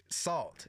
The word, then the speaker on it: Sault: But check out Salt. It's spelled S-A-U-L-T.